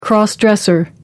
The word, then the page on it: cross-dresser: American English